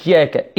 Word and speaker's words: إياك: كما تنطق إياك الأولى تنطق الثانية، ولابد من تشديد الياء هنا، وإلا قد تخرج عن معناها.